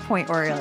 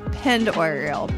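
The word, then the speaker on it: Pend Oreille: It's not Point Oriel, it's Pend Oriel.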